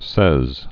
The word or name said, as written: Louis Seize